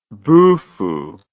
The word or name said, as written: bufu